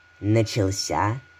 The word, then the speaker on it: начался: В глаголе «начался» ударение падает на последний слог, хотя в инфинитиве ударение перебегает на второй – «начаться». Именно это и вызывает путаницу. Здесь возможен только единственный верный вариант произношения – с ударением на последнем слоге.